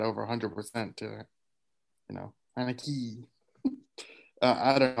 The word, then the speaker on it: Heinicke: Yeah, and you know, Taylor Heinicke played pretty well on Sunday, but wait, what's the quarterback's last name? – Heinicke. – How do you say it? – Heinicke. – There you go.